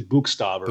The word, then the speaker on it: Buchstaber: So it's German and we don't say it correctly. It's actually Buchstaber. – Buchstaber. Right. So we're not talking about stabbing a buck deer.